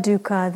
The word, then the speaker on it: dukkha: So this word dukkha, the reason we keep using the Pali is because, and it's spelled D-U-K-K-H-A, in case you can't quite kind of grasp the tones when we say it.